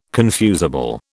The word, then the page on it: confusable: British English